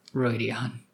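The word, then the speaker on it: Rhuidean: No, it's pronounced Royd. Never mind. I've listened to the audiobook for this book. Michael Kramer pronounces it Roydian, and that's good enough for me.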